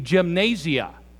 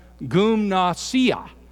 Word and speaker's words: gymnasia: Now, if I were going to pronounce that word from an English point of view, obviously it would be gymnasia. That's not how you pronounce it in the Greek. It's called gymnasia.